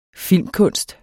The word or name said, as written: filmkunst